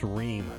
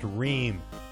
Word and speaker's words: Ream: It's pronounced Reem. – What? – My last name, it's pronounced Reem. You said meme? – No, I mean, do you like memes?